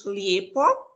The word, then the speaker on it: lijepo: Äh, ich sagt er immer so ein Beispiel, äh, das Wort schön heißt bei uns auf Kroatisch Lijepo und auf Serbisch heißt es Lepo.